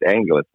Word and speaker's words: Anglis: Marco Anglis, if I'm saying his name properly, it's like Angels, but it's Anglis, Marco and Susanna Anglis.